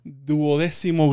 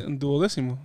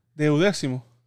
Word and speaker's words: duodécimo: también el ah, jueves eh, tuve la oportunidad de celebrar misa con los seniors, los del último, los del duodécimo grado. – 12, ¿eh? 12, eh, duodécimo. Se dice así. – Do, Ajá. – Deudécimo. Duodécimo.